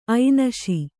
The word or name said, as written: ಐನ್‍ಷೆ